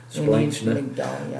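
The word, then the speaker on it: Splanchna (σπλάγχνα): Again, that third one there, splank. No. Is that how you say it in the Greek? Splanchna.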